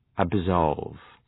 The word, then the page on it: absolve: æb’zɒlv, æb’sɒlv